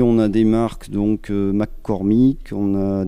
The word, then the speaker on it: McCormick: En tout cas, cette marque américaine, il s'agirait de Deering McCormick ou Cornick. – Alors on a de la marque Deering et on a des marques McCormick également, oui.